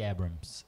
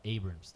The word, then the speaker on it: Abrams: A Kamala Harris, e a Stacey Abrams, uh, Abrams, dependendo da pronúncia.